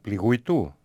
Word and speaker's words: πληγουητού: Να ακούσουμε το πληγουητού. Είπατε, έτσι προφέρεται. Ναι.